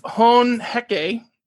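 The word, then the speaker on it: Hone Heke: After they had done this, Chief Hon Heke, I think is the way it's pronounced, yes.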